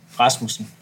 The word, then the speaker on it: Rasmussen: Rasmussen.